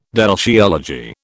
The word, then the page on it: deltiology: British English